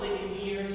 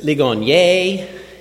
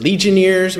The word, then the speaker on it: Ligonier: Is it Ligonier? Is it Ligoniers? Is it Legionnaires? We get all kinds of pronunciations. So thank you for, first of all, pronouncing it right.